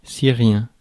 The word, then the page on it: syrien: [si.ʁjɛ̃]; France